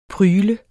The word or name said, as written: prygl